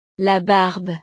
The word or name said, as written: la barbe